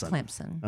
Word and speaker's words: Clemson: I don't know how you pronounce Clemson. – It's Clemson.